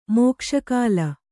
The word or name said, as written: ಮೋಕ್ಷಕಾಲ